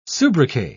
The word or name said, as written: soubriquet